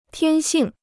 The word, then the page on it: 天性: tiān xìng